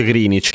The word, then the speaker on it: Greenwich: che si scrive Greenwich ma si legge Greenwich,